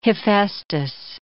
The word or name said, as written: Hephaestus